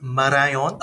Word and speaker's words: Malayan: So in Japanese, all right, the Malayan, pronounced Marayon,